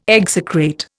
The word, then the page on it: execrate: Indian